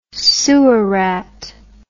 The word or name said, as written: sewer rat